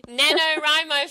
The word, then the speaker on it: NaNoWriMo: And watching the – I always say it wrong, the Ni Hao Ma thing that's been going in November. – NaNoWriMo for those people who are listening.